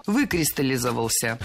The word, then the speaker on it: выкристаллизовался: Кристаллизовался, закристаллизовался, откристаллизовался, но выкристаллизовался. Произносить это слово довольно сложно, так как ударяем первый слог, а потом идет много безударных слогов. Но это норма. Выкристаллизовался.